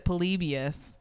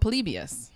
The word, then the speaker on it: Polybius: I think it's, so I watched a trailer for it and it was, they pronounced it Polybius. – Polybius. – So that's how I'm going to pronounce it and just hope that that nerd was right.